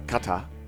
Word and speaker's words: Qatar: He is here from Australia via Doha, Qatar. Did I say that right? – Yeah. – Okay, good, good, good. – You could go Qatar.